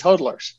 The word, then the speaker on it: hodlers: So you guys are all pronouncing it wrong. It's hodlers.